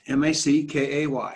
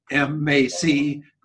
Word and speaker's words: Mackay: We got to make sure we get that right. – Mackay. – That's right. – Okay. – Mackey is the way you spell it. That's perfect. Mackay.